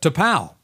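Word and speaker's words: T'Pau: T-Pow.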